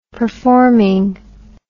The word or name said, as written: performing